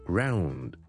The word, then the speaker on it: ground: 上一集有听众问招叔叔两个字嘅发音，第一个系 ounce 同 ounces 安士，第二个字系 ground G R O U N D。咁呢两个字咧，佢哋个共通点咧就系我哋都系有个 ow ow ow 嘅音。